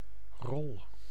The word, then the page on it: character: /ˈkʰæɹɨktɚ/; /ˈkʰɛɹɨktɚ/; /ˈkʰæ.ɹək.tɚ/; /ˈkʰɛ.ɹək.tɚ/